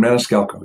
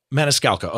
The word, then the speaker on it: Maniscalco: This is a multi-shot composite of the comedian Sebastian Maniscalco. Did I pronounce his name correct? – Sebastian Maniscalco, yep. – Maniscalco, okay.